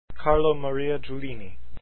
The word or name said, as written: Giulini